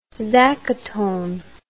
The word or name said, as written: zacaton